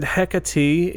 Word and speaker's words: Hecate: But they pronounce it Hecate, is how I saw the actual Greek pronunciation of that is, but they pronounce it a little differently in, like, Shakespearean, texts and things like that, and it's just like Hecate or Hecate. But they said Hecate is the proper pronunciation, so that's what I'm rolling with.